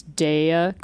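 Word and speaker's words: Daiya: Can I just acknowledge that you pronounce Daya correctly? – Oh, did I? Okay. – Yes, everyone wants to call it Daya.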